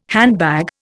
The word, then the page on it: handbag: Indian